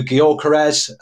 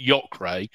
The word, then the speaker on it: Gyökeres: He nudges it across to Gyökeres, and that's it. 3-1. – I'm glad you pronounced that. – Thank you very much. – I was going to pronounce it Yokre.